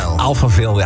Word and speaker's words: Alphaville: Ja, hoe spreek je dit uit? – Opa-file. – Alphaville, ja, wat je wil.